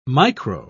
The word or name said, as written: micro